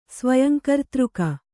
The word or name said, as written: ಸ್ವಯಂಕರ್ತೃಕ